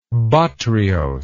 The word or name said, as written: botryose